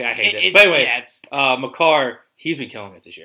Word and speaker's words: Makar: Kale Maker, yeah. – Makar.